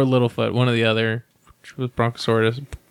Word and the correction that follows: Brontosaurus: I can't pronounce it. – Bronto. – Brontosaurus, thank you.